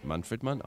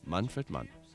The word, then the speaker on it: Manfred Mann: Aus einer persönlichen Begegnung mit ihm weiß ich übrigens und möchte es gern weitersagen, Manfred Mann spricht sich Manfred Mann aus und nicht Manfred Mann und nicht Manfred Mann und nicht Manfred Mann, sondern Manfred Mann. Also richtig treu deutsch.